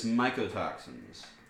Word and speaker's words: mycotoxins: Is it pronounced mycotoxins or mycotoxins? – I believe it's mycotoxins. – Mycotoxins? – Yeah, like mycology, mushrooms.